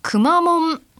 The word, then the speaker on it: くまモン: ちょっと前の話になりますが、シャープ 75 でくまモンの発音について今迷っちゃったな、福岡のテレビ局 TNC のアナウンサーは、みなくまモンというイントネーションだったという話をされていましたね。こう平板という、アクセントですね。